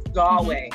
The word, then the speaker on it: Galway: Galway.